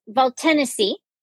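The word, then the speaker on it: Valtènesi: It's Valtanesi and it's Chieretto.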